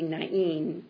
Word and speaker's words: Nain: And I thought it would be Nain, but I was told it was Nain, which is unusual. But that might be more of a modern Hebrew pronunciation.